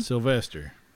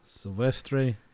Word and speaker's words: Silvestre: I'm sure I butchered that, but Silvestre. But it was the R-E instead of E-R. So Silvestre Vélez de Escalante.